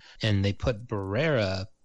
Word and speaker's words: Barbera: So it's from Northern Italy, probably more close to pronounced Barbera, given that it's Italian.